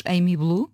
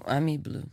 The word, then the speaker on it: Amy Blue: Sei se pronuncia Ami Blue ou se diremos Amy Blue. – Eu digo Ami Blue.